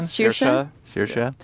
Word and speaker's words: Saoirse: There's another important relationship, which is between Zero and Agatha, who's played by, I believe it's pronounced Saoirse Ronan? – Saoirse.